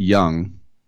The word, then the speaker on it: Jung: Jace Jung, he pronounces it Young.